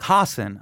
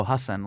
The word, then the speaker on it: Hasan: They'd be like, Hasan. I'm like, jeez. All right. No, Hasan. Like, and I realized the way vowels and consonants are pronounced in the English language, it makes certain names from the South Asian subcontinent very difficult to pronounce.